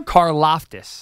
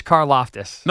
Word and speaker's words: Karlaftis: Karlaftis. – God bless you. – George Karlaftis.